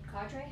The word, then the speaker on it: cadre: Yeah, our cadre, how do you say it? – Cadre.